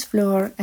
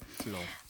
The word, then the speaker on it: Flor: Hi guys, my name is Flor, and if you have trouble pronouncing that, it's okay, Flor. But I bet you can ask Daniel, because my name is Flower in Spanish, yeah. So he'll know how to say it. Flor.